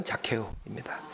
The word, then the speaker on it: 자케오: 예, 키 작은 삭개오, 뽕나무에 올라가 있는 키 작은 삭개오의 영어식, 아니죠, 삭개오가 중국식 표기이고 이제 원래 발음은 자케오입니다.